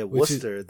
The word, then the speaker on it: Worcester: I was like, yo, I hope she does not mispronounce it to the people there, because she kept calling it Worcester, yes, which is how it's spelled.